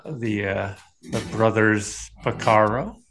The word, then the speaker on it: Porcaro: Oh, you said it right this time. – yeah, you, you made me nervous. I was panicking. you typically you would say Pacaro. Yeah. or Caro, Pacaro. Yeah.